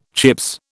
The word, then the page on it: Chips: British English